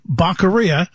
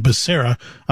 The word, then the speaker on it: Becerra: Here we have Baccaria. I always like to call him that because of, you know, the former president's mispronunciation of his name, Becerra, Javier Becerra.